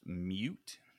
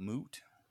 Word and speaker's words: mute: It's pronounced mute, not moot.